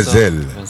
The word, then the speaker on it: Zélie: Chez les Malgaches, il ne faut jamais prononcer la dernière lettre. – C'est Zel.